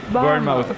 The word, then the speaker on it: Bournemouth: Bournemouth.